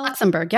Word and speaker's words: Luxembourg: Luxembourg, yep.